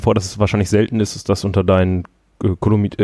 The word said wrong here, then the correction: Kommilitonen: äh, ich kann das Wort so schlecht aussprechen. – Kommilitonen. Ja, danke ja.